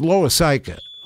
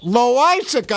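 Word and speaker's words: Loáisiga: Loisica, did I say it right? It was Loisica. Loisica, I got it right for the first time in my life.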